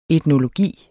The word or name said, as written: etnologi